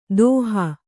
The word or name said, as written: ದೋಹ